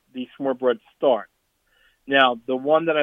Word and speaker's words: smørrebrød: There's a dish, I'll spell it first. It's SMORRE and then BROD. I think it's pronounced smørrebrød.